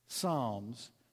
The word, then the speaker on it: Psalms: It's like Pflugerville. The P is silent. Psalms. P-S-A-L-M-S.